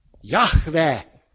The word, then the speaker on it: Jahve: Tehát J, H, V, H, Jahve! Szóvégi hát nem kell kiejteni.